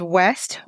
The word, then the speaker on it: Wes: His name is West Wages. Hopefully, I said that right. – Yeah, just no T, just Wes.